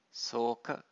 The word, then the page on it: soka: Pali